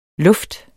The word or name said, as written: lufte